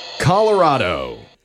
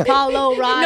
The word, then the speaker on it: Colorado: Colorado. – No, the A. Call. Like call. – Call. – Polo. Oh, good point. – I thought it was like color.